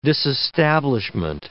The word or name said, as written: disestablishment